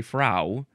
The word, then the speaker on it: frow: How would you pronounce this? I think you would say fro. You could also maybe say frau, but I think it's fro. F R O W.